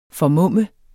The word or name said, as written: formumme